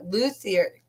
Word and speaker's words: luthier: including he was a luthier.